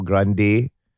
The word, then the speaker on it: grande: So for context, like a caramel macchiato at Starbucks in Malaysia costs RM16.96 for grande. Is that how you pronounce it? – Yes. – Grande, yes.